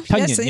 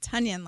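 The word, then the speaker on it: Tonyan: Is that how we say it now? – Tunyon, yes. – We've been mispronouncing it. Tunyon, like Funyuns.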